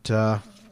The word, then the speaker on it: Ahmadinejad: But Ahmadinejad.